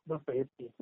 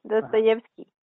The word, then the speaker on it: Dostoyevsky: Dos, Dostoyevsky o cómo? – Dostoyevsky. – Dostoyevsky.